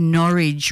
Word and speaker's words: Norwich: I should have spelled Norwich because it's not Norwich. It's Norwich rhymes with porridge.